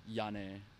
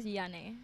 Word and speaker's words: Jyanne: I mean, like, people here call me Jane, but my name is, like, Jyanne. – Jyanne. – Okay, I'll call you Jane. – Okay, cool. – Okay. – Oh, it's actually pronounced as Jyanne.